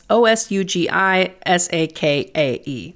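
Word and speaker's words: Ōsugi Sakae: This is Osugi Sakai. That's Osugi Sakae, in case I'm totally butchering that.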